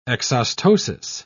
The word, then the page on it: exostosis: British English